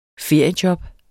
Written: feriejob

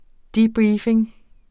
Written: debriefing

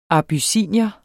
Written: abyssinier

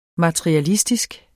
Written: MATERIALISTISKE

